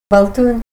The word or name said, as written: baltun